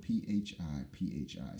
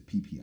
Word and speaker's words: Phi Phi: Real quick note, it's spelled P-H-I-P-H-I, so you think it's Phi-Phi Islands. And you think that Phuket is, you know, people pronounce it fuck it, Phuket, whatever. So the H is silent. So it's not the Phi-Phi Islands, it's the PP Islands.